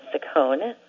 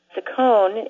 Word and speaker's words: Ciccone: Ciccone, if you pronounce it Ciccone, is also Madonna's last name.